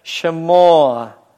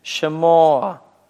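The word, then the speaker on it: шемоа (שמע): Шмоа. Вот, следуя правилам Левиты, мы шва, находящаяся под первой буквой слова, читаем, да, произносимое, вот, ше, поэтому мы легкий призвук е вставляем, ше, шемоа, шемоа, дальше, гавоа, гавоа.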